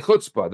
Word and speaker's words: chutzpah: And chutzpah, you say chutzpah.